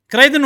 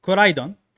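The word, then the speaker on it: Koraidon: اذا بقول النطق الصح الياباني خلينا نقول او مو الياباني لان هم مستوحى من كلمه يابانيه عشان كذي بقول النطق اقرب للياباني ان اللي البرتقالي اللي بسكارلت اسمه كورايدون واللي بالفايلت اسمه ميرايدون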